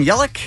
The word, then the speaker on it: Yelich: We have Christian Yelich. Is that how you say his name? Plays for the Brewers? – Yeah.